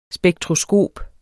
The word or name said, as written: spektroskop